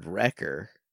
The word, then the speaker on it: Wrekkr: Next, also from Christina, I've got a Dredagon who I named Wrecker, W-R-E-K-K-R, pronounced Wrecker with a fantasy spelling because she wrecks shop.